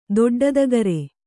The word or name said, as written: ದೊಡ್ಡದಗರೆ